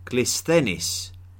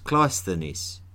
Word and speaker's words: Cleisthenes: Clisthenes, often pronounced Cleisthenes, had some very clear ideas about how Athens should be ruled.